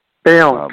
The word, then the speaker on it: Bayonne: Now you take us in the book to December 18th and 1958, and tell me if I'm mispronouncing this, it's Bayonne, New Jersey. – Bayonne, correct, yes.